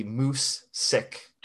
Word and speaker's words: musig: And for our non-Swedish audience, we say the closest pronunciation to that is really moose sick.